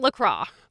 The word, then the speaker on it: LaCroix: It is 100% confirmed LaCroix, not LaCroix. – LaCroix rhymes with joy.